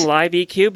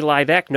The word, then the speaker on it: LiveEQ: It's supposed to be Eck. – Yeah, like, well, I told her that I'm saying Live EQ because when I say Live Eck, nobody will know how to spell it. – Okay, well, that works too. So it's actually pronounced Live Eck.